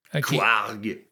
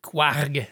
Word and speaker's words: Kouarrg: C'est, euh, il s'appelle Quarg. Quarg? Comment t'écris ça? Euh, Attends, comment t'écris ça? K-O-U-A-R-R-G. – Quarg. – Quarg. – C'est vraiment ça. C'est comme ça se prononce.